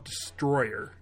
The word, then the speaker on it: Destoroyah: In the movie they call it Destroyer. I always thought it was Destoroyah, but it's spelled out Destoroyah.